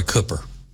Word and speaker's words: Cooper: You can't be informed and decent and vote for Roy Cooper. And the reason I say his name Cooper, because that's the way he pronounces it.